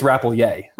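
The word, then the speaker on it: Rappleyea: Tell us about Penn State tight end Andrew Rapoye. Am I saying that right? – I think it's Rapoye. – Rapoye. Okay. All right, so Rapoye.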